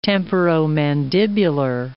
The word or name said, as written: temporomandibular